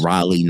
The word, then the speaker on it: Raleigh: I go to this little place called Raleigh, North Carolina. I believe is how they say it there. – Very close. – It's Raleigh, North Carolina.